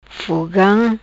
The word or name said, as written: Fogam